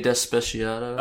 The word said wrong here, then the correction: Despacito: Oh, Despacito? – Despacito. – You say that wrong every time.